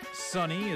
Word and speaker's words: Sonny: Sunny is spelled S-O-N-N-Y.